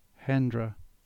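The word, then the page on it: Hendra: Cornish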